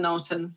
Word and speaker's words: Knowlton: So it's spelled like Hill and Knowlton, so Knowlton.